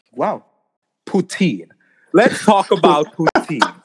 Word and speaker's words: poutine: That's the correct way. That's the French way of saying it. Oh, okay. Poutine.